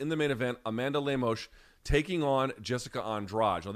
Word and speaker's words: Lemos: UFC Fight Night, Lemos, or I'm told, by the way, it could be pronounced Lemos, depending on where you're from in Brazil, versus Andrade.